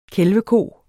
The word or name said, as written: kælveko